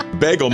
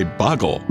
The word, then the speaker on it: bagel: Bagel. Most people say bagel. – Bagel, of course. – But for some reason, there are some Midwesterners that say boggle.